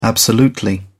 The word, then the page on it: absolutely: British English